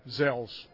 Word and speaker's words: Gsell's: Gsell's is spelled, and in case you want to look us up online, it's G-S-E-L-L-S. Gsell's, it's pronounced like it's a Z, but it's a G-S-E-L-L.